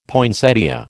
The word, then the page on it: poinsettia: American English